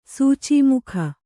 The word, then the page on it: ಸೂಚೀಮುಖ: sūcī mukha